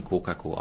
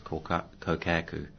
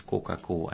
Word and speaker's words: kōkako: And I must mention, kōkako aren't pronounced kōkaku, because a lot of people pronounce the O with a kōkaku. It's kōkako, and they are just a phenomenal bird.